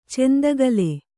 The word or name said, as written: ಚಂದಕಲ